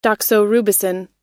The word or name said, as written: doxorubicin